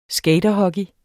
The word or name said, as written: skaterhockey